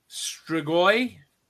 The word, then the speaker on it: Strigoi: Eli just said, the wolves you think of are the Romanian Strigoi. Did I pronounce that right? I'm pretty sure I did. That's my heritage. – That looks right.